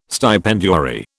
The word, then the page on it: stipendiary: British English